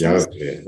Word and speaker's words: Yahweh: 그래서 Yahweh is probably the best pronunciation of that word.